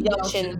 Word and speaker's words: Yelchin: Yelchin.